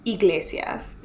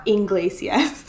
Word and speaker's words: Iglesias: Okay, first of all, Iglesias, not Iglesias.